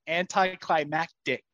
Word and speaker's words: anticlimactic: That's not how you say it. – What do you say? – Anticlimactic. I can't even say it now. Anticlimactic. – No, that's not right. – This is one of those where you say the word so many times. – No, say it right wrong. – Now. Ready, set, go. – Anticlimactic. – Anticlimactic. – Okay, emphasis on the wrong syllable.